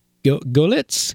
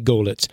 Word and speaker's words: Goelitz: Well, you know, when the, how do I pronounce this? G-O-E-L-I-T-Z. Golitz? I'm going to say Golitz. The Golitz Confectionery Company first produced candy corn.